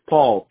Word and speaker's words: Paul: It's Paul, P-A-U-L.